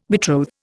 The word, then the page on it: betroth: Indian